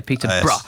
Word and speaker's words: Peterborough: Peterborough.